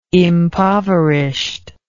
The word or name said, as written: impoverished